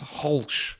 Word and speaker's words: Grolsch: But when it comes to Dutch beer, I do love a Grolsch, pronounced Holsch, apparently.